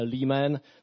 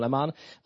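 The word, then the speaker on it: Leman: Oni točil teda v angličtině Lehman, v Čechách se spíš používá jenom, jako to možná vyslovujeme blbě, Kevin Lehman.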